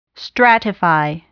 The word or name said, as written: stratify